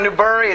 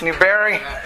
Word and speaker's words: Newberry: Newbury, it's not Newbury, it's Newberry.